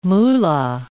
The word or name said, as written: moolah